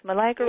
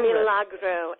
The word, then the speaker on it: Milagro: Milagro. – Milagro. – Milagro.